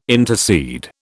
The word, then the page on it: intercede: British English